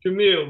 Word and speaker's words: Jermael: It's Jermael, but you're good.